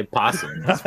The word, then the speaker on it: opossum: Do you actually pronounce it opossum? – No, I don't think so. – I just say possum.